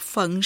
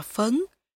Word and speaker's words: phấn: Mẹ tôi nói, đúng rồi, viết là phận, nhưng nếu mình đọc phận sao phận bạc như vôi, thì mình cũng đang than thở như Kiều vậy. Mình sẽ giận nó vào trong người, rất nguy hiểm, thành ra phải đọc là phấn, để chứng tỏ là mình khác.